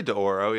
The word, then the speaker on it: d'Oro: How do you say it? D'Oro, lowercase d apostrophe capital O. D'Oro? – I'd say D'Oro, yeah.